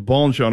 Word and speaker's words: Bonchon: Okay, with my Alabama dialect and all that, I want to make sure I'm saying your name correctly and Bonchon.